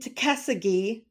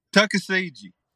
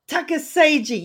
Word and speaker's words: Tuckasegee: He lives in Tuckasegee, North Carolina. I hope I pronounced that right, David. No. What was it? Say it for me. – Tuckasegee. – Tuckasegee.